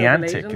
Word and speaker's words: Niantic: Niantic, isn't that how you pronounce it? – Yeah, the new Pokemon Harry Potter Go.